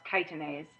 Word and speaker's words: chitinase: It's spelled chitinase, but it's pronounced chitinase.